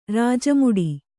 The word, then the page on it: ರಾಜಮುಡಿ: rāja muḍi